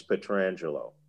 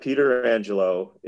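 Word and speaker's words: Pietrangelo: I will say this, that if you ask Alex Petrangelo how he pronounces his name, he will tell you it's Alex Petrangelo. It's not Peter Angelo. It's not Petra Angelo or any other kind of bastardization of the name.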